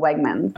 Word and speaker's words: Wegmans: It's interesting that because that last, was it Wagman, was it? How do you spell it? – It's Wegmans, so Wegmans. – Oh, Wegmans.